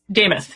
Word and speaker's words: Demuth: Well, they debate, is it DeMuth? How does she pronounce her name? Lisa DeMuth? How does she say it? – Damuth. Yep.